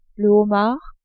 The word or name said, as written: homard